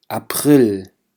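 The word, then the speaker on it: April: فيبروار، مارس، مارس، أبريل، أبريل. لاحظوا هون حرف الـ A لفظته بشكل واضح وصريح، أبريل.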